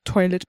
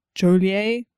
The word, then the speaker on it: Joliet: Now, I'm going to pronounce this toilet because that's what it looks like to me. It's Joliet.